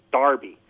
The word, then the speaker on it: derby: Americans, we call it a derby, like the Kentucky Derby, and that's how it's spelled there, but they pronounce it Darby.